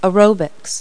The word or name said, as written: aerobics